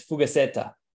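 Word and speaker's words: fugazzeta: I want Axel to tell us about Argentinian pizza, specifically a monstrosity that is known as fugaceta. Did I pronounce it correctly, Axel? – Yes, you did.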